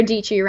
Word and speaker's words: Dietschy: So my name's Sara Dietschy, rhymes with peachy.